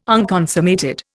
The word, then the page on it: unconsummated: Indian